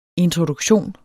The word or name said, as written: introduktion